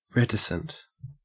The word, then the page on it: reticent: Australian English